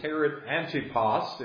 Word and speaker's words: Antipas: In other places, he's referred to as Herod Antipas, if I'm pronouncing that correctly, A-N-T-I-P-A-S.